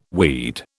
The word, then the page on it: weed: British English